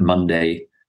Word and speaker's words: Munday: I will just say quickly that Munday, to make things more difficult, is spelled slightly differently. M-U-N-D-A-Y. M-U-N-D-A-Y.